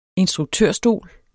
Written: instruktørstol

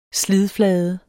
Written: slidflade

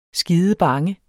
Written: skidebange

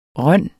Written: rønne

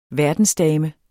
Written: verdensdame

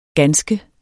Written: ganske

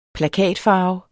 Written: plakatfarve

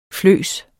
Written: fløs

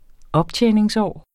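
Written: optjeningsår

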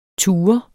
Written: TUREDES

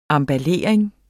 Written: emballering

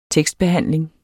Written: tekstbehandling

